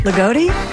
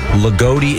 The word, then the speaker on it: Loogootee: L-O-O-G-O-O-T-E-E. – Lagodi? – Lagodi, Indiana.